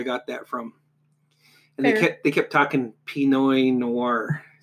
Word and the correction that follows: Pinot Noir: Pinot Noir.